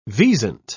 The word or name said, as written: wisent